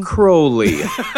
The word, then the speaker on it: Crowley: Crowley is how you pronounce it.